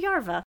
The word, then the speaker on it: Jarva: I mean, I'm assuming in that area of the world, it's usually like a J as a Y. – It's usually like a yeah. – Yeah, okay, sure. So let's say Risto Jarva, but it's spelled Jarva.